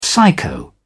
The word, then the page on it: psycho: British English